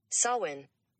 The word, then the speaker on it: Samhain: Again, it's spelled S-A-M-H-A-I-N, but it's Samhain.